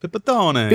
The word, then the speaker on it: Pipitone: And how do you say your last name? Pipitone? – Yeah, if we were in Italy, they'd be like, Pipitone. – Pipitone. – But here...